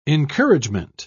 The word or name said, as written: encouragement